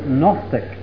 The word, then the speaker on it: Gnostic: In the first century they were called Gnostics. Now I'll spell that because I want you to look it up. That is G-N-O-S-T-I-C-S. Gnostic. Now the G is silent. It's a Greek word. It comes from the Greek word gnosis. G-N-O-S-I-S.